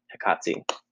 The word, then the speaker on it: Hecate: The actual name, Hecate, people pronounce it differently. Some people say Hecate, some people say Hecate, some people say Hecate. But the correct pronunciation from Greek is Hecate.